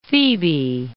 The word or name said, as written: Thebae